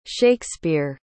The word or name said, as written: shakespeare